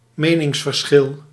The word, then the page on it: debate: American English; /dɪˈbeɪt/